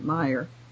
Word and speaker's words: Mayer: The other guy's name is spelled M-A-Y-E-R, and you are pronouncing it Meyer.